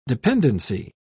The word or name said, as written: dependency